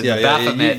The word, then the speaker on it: Baphomet: And basically, the temple, the Satanist temple, alleges that the defendants, which would be Netflix, misappropriated the TST Baphomet. And I'm saying that right, because I know... – Right, yes. Baphomet, you're down with the vernacular.